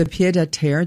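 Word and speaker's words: pied-à-terre: So the pied de terre, did I say that right? – Yes, that is the second home tax.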